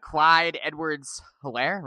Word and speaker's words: Edwards-Helaire: And you're talking about Clyde Edwards-Helaire, right? Am I pronouncing that correctly? – Correct.